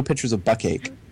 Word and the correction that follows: bukkake: Bukkake, that's pronounced, actually. – Oh, is that how it's pronounced?